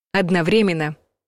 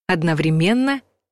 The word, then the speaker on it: одновременно: Наречие «одновременно», как и прилагательное «одновременный» — удивительные слова, в которых ударение в современном русском языке может падать и на третий, и на четвертый слог, то есть варианты равноправные.